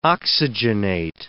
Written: oxygenate

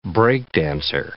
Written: break-dancer

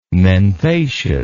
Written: menthaceous

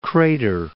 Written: Crater Lake

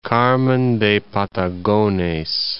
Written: Carmen de Patagones

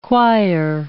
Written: quire

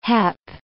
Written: hep

